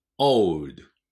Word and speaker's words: old: old, old. これも o ね。 old じゃないですよ。 o, o。 o と u ですね。